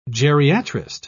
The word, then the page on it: geriatrist: British English